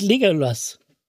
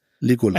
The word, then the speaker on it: Legolas: Hatte ich neulich mal Legolas. – Einen freien Abend. – Legolas. – Legolas? – Ja. Was habe ich denn gesagt? – Nein, es ging um die Betonung auf Lego.